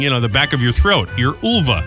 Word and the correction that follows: uvula: That's a uvula. – Oh, uvula. – So should I say ova or uvula? – It's uvula. That's okay, how it's pronounced.